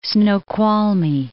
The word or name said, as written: Snoqualmie Falls